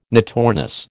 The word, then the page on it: notornis: American English